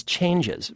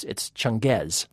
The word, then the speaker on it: Changez: His name, interestingly enough, I'll spell it, Changez, which his boss, Kiefer Sutherland, keeps pronouncing as changes, but in fact it's not. It's Changez.